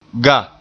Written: ga

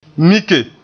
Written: mike

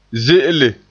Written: ze'ele

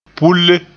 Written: pulle

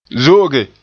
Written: zo'oge

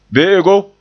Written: be'ego

